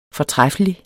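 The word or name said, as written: FORTRÆFFELIG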